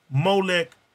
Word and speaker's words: Molech: And the name of that owl is pronounced Molech.